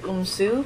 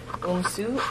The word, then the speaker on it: Oumsou: Sabai Tip Umsu. Would you like me to spell it? – Please. – S-A-B-A-Y-T-H-I-P. Umsu. O-U-M-S-O-U.